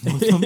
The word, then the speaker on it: Northam: Actually, I've been pronouncing his name wrong the whole time. Ralph Norman. I just want to make a correction. – It's Ralph Northam.